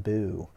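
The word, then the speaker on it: BHU: It's pronounced boo.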